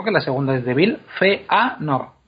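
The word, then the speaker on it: Fëanor: A nosotros, se quedan muy bonitas, una palabra con sus dos puntitos encima de la E, está muy bien, como Feanor, pero no significan absolutamente nada en la pronunciación.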